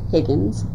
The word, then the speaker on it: Higgins: Higgins, N-A-N-C-Y, J, H-I-G-G-I-N-S.